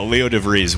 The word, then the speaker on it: DeVries: And once the Padres made DeVries, or that's how you pronounce his name, right? – Leo DeVries? – Yeah, Leo DeVries.